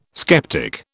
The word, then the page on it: sceptic: British English